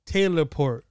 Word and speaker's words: Taylor Port: So, no, it's called Taylor Port. – Oh, Taylor? – I pronounce it teleport. – T-A-Y? – Taylor, yes.